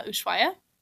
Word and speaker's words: Ushuaia: Uh, Ushuaia? – Yeah.